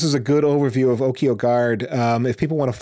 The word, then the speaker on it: Okyo: And Okyo, if you're listening, is spelled like Tokyo, but without the T. So O-K-Y-O.